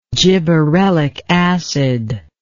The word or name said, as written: gibberellic acid